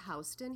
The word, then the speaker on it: Houston: I'm kind of from all over, but I was in high school, I was in Houston, Texas. So like in a city. – Mm-hmm. Well, we pronounce it Houston here.